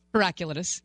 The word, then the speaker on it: Heraclitus: Heraclitus? How do you say it? – Heraclitus. – Right. You just added a syllable. – Well, yeah. – And obviously, it's a silent syllable.